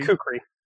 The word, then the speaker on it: kukri: That's how you say it, kukri? – Kukri, yes.